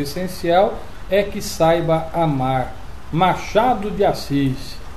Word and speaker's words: Machado de Assis: Machado de Assis.